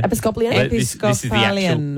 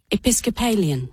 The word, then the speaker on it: Episcopalian: Episcopalium. – Okay. – Episcopalian. – This is the actual pronunciation. – Episcopalian.